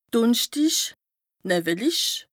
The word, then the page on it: dùnschtich, nëwwelich: Bas Rhin; Strasbourg